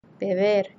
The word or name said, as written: beber